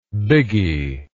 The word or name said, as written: biggy